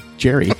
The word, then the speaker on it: Jerry: I'm like, I didn't say Jeremy. I said Jerry.